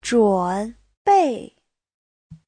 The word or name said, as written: zhun3bei4